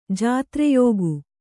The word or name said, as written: ಜಾತ್ರೆಯೋಗು